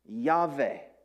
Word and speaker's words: Yahweh: Below that is the English word, the English letters, YHVH, and it would have been pronounced Yahweh, Yahweh.